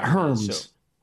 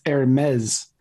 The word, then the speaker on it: Hermes: It's not Hermes, which is how it's spelled. It's Hermes.